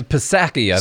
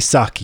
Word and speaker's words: Psaki: I'm going to tell you about, is this Psaki? I think is how you say it. – Psaki Sake. – Is it Psaki? – Not to be confused with the drink Sake.